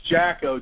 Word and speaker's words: Jackow: See, my last name, I always was a Jockeman fan because my last name is pronounced Jacko, J-A-C-K-O-W.